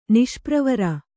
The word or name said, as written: ನಿಷ್ಪ್ರವರ